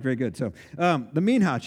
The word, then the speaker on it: Minha: Um, this is the minha chapter 2, M I N H A H, Minha is how you say that.